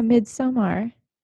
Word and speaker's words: Midsommar: Yeah, Midsommar. That's how you say it.